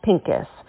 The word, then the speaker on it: Pincus: Hello, my name is Meeg Pincus. Meeg rhymes with league, and Pincus is like the color pink plus us.